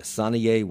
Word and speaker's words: Saniye: Sanaye Wilson, S-A-N-I-Y-E.